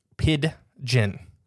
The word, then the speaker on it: Pidgin: Pidgin. – Pidgin. – Yeah. There's a D in the middle. – Right. – So it's like the bird.